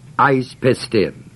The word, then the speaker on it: eis pistin: The Greek words, of course, you could look up in any interlinear, but they are ek pisteos, eis pistin, E-K-P-I-S-T-E-O-S, long O, E-I-S-P-I-S-T-I-N.